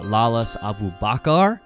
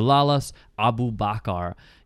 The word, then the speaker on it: Lalas Abubakar: So I went with uh, Lalas Abubakar I looked up that pronunciation too to make sure I had it right. So Lalas Abubakar, he is a center back for the Colorado Rapids.